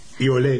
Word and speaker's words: Iole: Entonces, Había una persona, yo no sabía si era un hombre o una mujer, y el nombre era I-O-L-E. I-O-L-E. Y le dije a Gordon, Gordon, ¿cómo se pronuncia este nombre? Y Gordon me dijo, Iole. Iole, ¿vale?